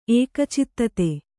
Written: ಏಕಚಿತ್ತತೆ

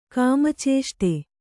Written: ಕಾಮಚೇಷ್ಟೆ

